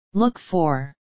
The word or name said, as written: look for